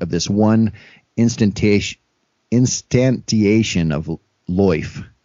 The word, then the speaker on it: lyfe: They propose introducing a broader category called loif, pronounced in an oddly West Country fashion as loif. It's spelled L-Y-F-E, of which loif as we know it is just one variation.